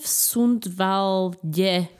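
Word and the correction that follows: Sundsvall: Sundsvale. strašné tieto výslovnosti.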